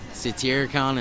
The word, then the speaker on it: Satyricon: Yep, Satyricon in Portland, Oregon.